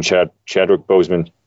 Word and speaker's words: Chadwick Boseman: But and it was nice hearing Chadwick Boseman. Is that how I say his name? – Yep.